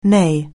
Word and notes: neigh: anglais

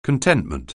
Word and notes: contentment: anglais

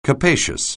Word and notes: capacious: anglais

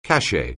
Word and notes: cachet: anglais

